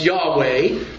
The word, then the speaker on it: Yahweh: Any time in most Bibles now, it's become a convention, that you have Lord in all capital letters, it's telling you that the word itself is most likely pronounced Yahweh. I don't think Jehovah really gets to it, but Yahweh, and that is the personal name of God,